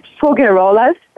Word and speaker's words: Faugerolas: My name is Faugerolas, just F-A-U-G-E-R-O-L-A-S.